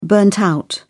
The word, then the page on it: burnt-out: British English